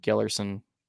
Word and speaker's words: Gellerson: Third place, Jake Gellerson. I actually said that right first. Okay, it's spelled way different than it sounds, and I got it right, so never mind.